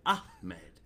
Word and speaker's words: Ahmed: Ahmed. – Yeah. You gotta extend the H. There's no C or K in there. It's Ahmed.